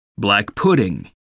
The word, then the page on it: black pudding: American English